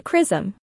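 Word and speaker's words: XRISM: The X-ray Imaging and Spectroscopy Mission, CRISM, pronounced CRISM, will analyze X-rays using the widest field of view instrument ever implemented in this kind of imaging probe.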